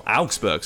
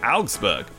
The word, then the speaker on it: Augsburg: And they got a last-minute winner away at Augsburg as well. Augsburg, sorry. I've got a bit better with my German pronunciation. Augsburg, Haaland.